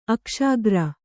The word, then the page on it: ಅಕ್ಷಾಗ್ರ: Kannada